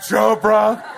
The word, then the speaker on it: Chopra: She's like, I mean, we love Tyler Perry, we love him, but really, not Gandhi, not even Oprah, or Deepak Chopra, which rhymes with Oprah.